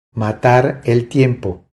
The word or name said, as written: Matar el tiempo